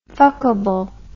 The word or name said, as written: fuckable